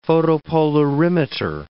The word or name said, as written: photopolarimeter